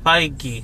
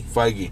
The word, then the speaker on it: Feige: Foggy, I think I'm pronouncing it right. Kevin Foggy? – Foggy. – Foggy. – Like Fi. – Feige. – Feige. – Feige, yep. Okay.